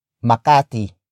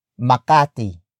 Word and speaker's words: Makati: And it's at Makati. Makati. Makati.